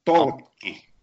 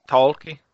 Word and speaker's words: Tolkki: We are here today with Timo Tolkki. I did pronounce that correctly, right? – Almost. It's Tolkki. – Tolkki? Okay.